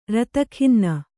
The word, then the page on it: ರತಖಿನ್ನೆ: rata khinne